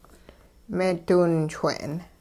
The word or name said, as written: meediwinchwe'n